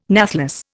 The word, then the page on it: nathless: Indian